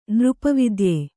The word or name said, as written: ನೃಪವಿದ್ಯೆ